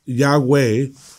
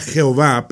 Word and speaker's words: Yahweh: Por eso, aun el nombre de Dios que, nosotros pronunciamos Yahweh, eh, antes era Jehová, pero se han dado cuenta de que eso era un error. Y, hoy día es, más conocido como Yahweh.